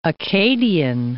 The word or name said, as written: Accadian